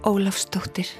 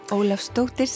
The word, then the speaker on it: Ólafsdóttir: Auður Ava Ólafsdóttir. Ólafsdóttir, c'est la fille de Ólafur, qui était le prénom de mon père.